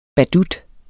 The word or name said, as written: badut